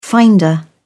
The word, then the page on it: finder: British English